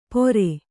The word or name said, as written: ಪೋರೆ